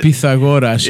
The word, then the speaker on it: Pythagoras: Is the correct Greek pronunciation the Pythagorean? – Is it? Pythagoras is how you say it.